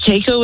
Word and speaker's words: Taiko: It's Taiko with a T, yeah.